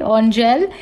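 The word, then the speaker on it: Angèle: That's how you say it, Angel.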